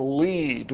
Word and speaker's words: lede: You start out with what we call the lead, which for obscure journalistic history reasons is spelled L-E-D-E.